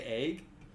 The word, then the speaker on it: egg: I typically say egg with an A sound as opposed to an E. – Fluctuate up. – Yeah, it's an egg. Like eggs and legs.